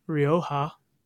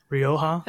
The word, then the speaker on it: Rioja: Called Rio Madre, Rioja, I believe is that. That's how you say it? – Yeah. – Rioja? – I think you got it.